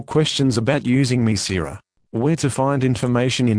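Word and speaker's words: Mircera: This is the consumer medicine information for Mesira, pronounced Mesira.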